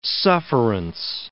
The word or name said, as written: sufferance